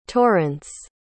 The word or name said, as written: torrence